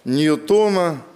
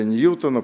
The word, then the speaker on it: Ньютона: Третий закон Ньютона, или Ньютона, пожалуйста, по-моему, по-разному можно ударение ставить.